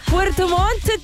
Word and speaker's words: Puerto Montt: Puerto Montt, que hay que pronunciar bien, pero algo tiene 2 T, 10 grados la temperatura la máxima.